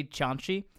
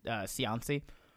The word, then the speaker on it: Cianci: His name was Buddy Cianci. It's the Italian way I pronounced it. Everyone just said Cianci.